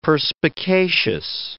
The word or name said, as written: perspicacious